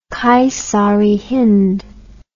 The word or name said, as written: Kaisar-i-Hind